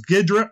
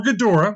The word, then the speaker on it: Ghidorah: Now, this name has been pronounced two different ways, so I'll pronounce it both ways. There's Ghidra or Ghidorah. Mm-hmm. That way I cover both bases.